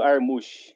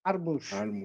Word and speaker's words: Harmuch: Fernando Armuch, meu nome. Armuch.